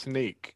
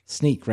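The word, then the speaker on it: Snyk: I thought it was SYNK, SNK. – I think it's pronounced Sneak. – Sneak, right? Sneak.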